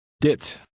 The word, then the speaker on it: dit: The Dutch I in this word is pronounced like the I in the English word hit. Try to read number 1. – Dit.